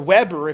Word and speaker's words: Weber: In fact, it's so successful and so well known that there was a man named Robert Weber, or Weber, if you don't like the German pronunciation, who came up with what is called the Weber thesis, which says that capitalism has its roots in the Reformed faith.